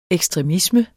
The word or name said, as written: ekstremisme